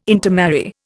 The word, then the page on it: intermarry: Indian